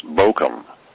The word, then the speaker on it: Bochum: How would you say Bochum in German? Do you know? – Bochum, I think in German that's pronounced Bochum.